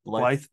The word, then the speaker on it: Blythe: I thought they were all really good, specifically Tom, say his name again, Blythe. – Yeah, Tom Blythe.